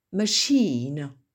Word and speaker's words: machine: qui s'écrit M-O-V-E. Regime, regime. Les deux prochains, on est étonné parce que le CH doit normalement se prononcer ch, comme dans par exemple chicken, et là il se prononce sh. Niche, machine, mute, deuxième mot, mute, draft, all beat, all beat, lettuce, Greenwich, Greenwich, infamous, dernier mot, gauge.